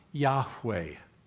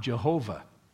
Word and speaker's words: Yahweh: It translates the Hebrew word YHWH, perhaps best pronounced Yahweh. God's name is not Jehovah. They don't have a J or a V in the original Hebrew alphabet. They could not have pronounced it. His name is Yahweh.